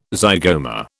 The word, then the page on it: zygoma: British English